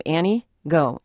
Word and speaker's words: Antigo: Antigo, or just like the woman's name, Annie Go, real quick, Annie Go.